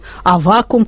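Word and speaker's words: Аввакум: это не «Раскол» вообще, а «Раскол» после «Смуты», вот «Никон», «Авакум», как сказал мне Николай, правильно делать такое ударение.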